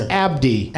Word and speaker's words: Abdi: Barkhad, you got right, but it's Abdi.